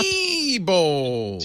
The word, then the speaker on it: Diebold: He said, "Diebold." It's Diebold. – Not Diebold. – It's spelled D-I-E. Diebold.